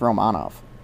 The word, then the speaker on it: Romanov: Apparently it's pronounced Romanov, which is weird.